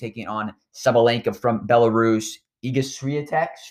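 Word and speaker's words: Iga Świątek: Iga Swiatek.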